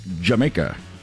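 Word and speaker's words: D'yer Mak'er: I know it's spelled like Dyer Maker, but it's pronounced Jamaica.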